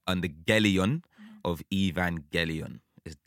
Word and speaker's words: Evangelion: So I have it on very good authority that it is Evangelion, hard G, like go.